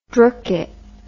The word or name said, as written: drookit